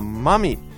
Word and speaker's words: mummy: everybody notice the pronunciation, a mummy. You can, kind of, you can say mummies are from Egypt. Right, mummies are from Egypt. Notice it's different from my mommy.